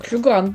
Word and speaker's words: Хью Грант: А, Хью Грант, правильно говорю? А, Хью Грант, да.